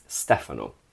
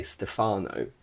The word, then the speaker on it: Stefano: His name is Stefano, but that's how we pronounce it in English, but in Italian, it's Stefano, Stefano, Stefano. But in English, we say Stefano, Stefano, Stefano.